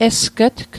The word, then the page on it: esg'tg: Mi'gmaq